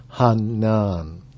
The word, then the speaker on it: Hainan: And just before we leave Hanan, how do you spell it? – It's spelled H-A-I-N-A-N. So, and it's sort of pronounced in the Vietnamese way of Hanan.